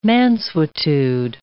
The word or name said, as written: mansuetude